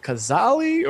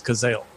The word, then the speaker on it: Cazale: So I was like, is it Kazali? Or then I actually looked up the pronunciation, so it is Cazale.